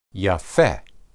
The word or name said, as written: yapheh